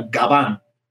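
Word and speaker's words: gaván: Like what we, what the first song we did is it's called Gabanjo, but it's actually a gaban, um, G A V A N. Uh, but you know the V in Spanish is pronounced like a B. It's labial, so gaban.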